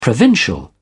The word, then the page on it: provincial: British English